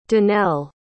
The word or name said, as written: danelle